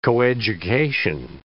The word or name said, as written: coeducation